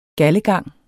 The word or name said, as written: galdegang